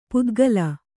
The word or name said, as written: ಪಿತ್ತಕಲ್ಲು